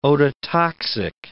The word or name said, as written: ototoxic